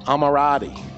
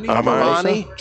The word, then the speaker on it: Armani: Giorgio Amirati. Italian fashion designer, American gigolo, Emirati. – Armani.